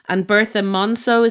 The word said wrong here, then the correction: Morisot: Is that how you pronounce her? – Morisot. Yeah. So she was actually she was radical for the 19th century.